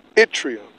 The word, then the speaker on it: yttrium: It's pronounced yttrium.